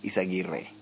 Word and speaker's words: Izaguirre: Jonas Aguirre, it was second, and please God, somebody tell the guys in Eurosport that his name is Izaguirre, not Izaguirre. There's a E at the end, Izaguirre.